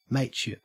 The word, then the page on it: mateship: Australian English